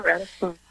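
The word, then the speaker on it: Ratisbonne: Is that how you say it? – Yeah, Ratisbon. Mm-hmm.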